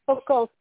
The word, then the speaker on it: Buchholz: Katrina, K-A-T-R-I-N-A. – Yep, last name? – Buchholz, B-U-C-H-H-O-L-Z.